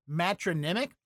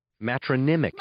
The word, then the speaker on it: matronymic: Annie, the pronouncer bot, who's a professional announcer, will tell us how to properly pronounce today's word of the day. – Matronymic. – Matronymic. All right. I got it.